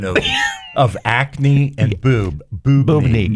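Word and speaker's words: boobne: And lastly, I had, so this word, B-O-O-B-N-E, I'm not sure how you pronounce it, boobney, I don't know.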